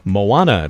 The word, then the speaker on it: Moana: We're going to get their names and the parts that they play in the production of Moana.